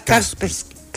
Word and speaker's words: Kaspersky: Kaspersky. Exactamente, ahí está bien, muy bien pronunciado.